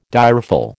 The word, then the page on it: direful: American English